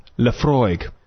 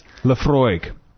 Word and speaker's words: Laphroaig: And so we just Google it, and a YouTube video comes up, and it's just a guy going, Laphroaig. – It's like 3 seconds long, and then it right. – ends. – Laphroaig.